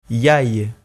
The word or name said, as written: iai